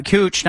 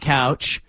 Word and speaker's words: Couch: It's couch, cooch, not couch.